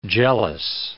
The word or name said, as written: jealous